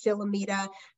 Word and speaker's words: Shilamida: My name is Shilamida.